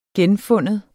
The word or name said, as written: GENFINDE